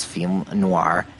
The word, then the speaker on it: films noirs: Right, and I had mentioned that I had studied French and that I thought that the correct pronunciation was film noir, FILMSNOIRS. And it turns out that I was right. So, but in French, neither S is pronounced.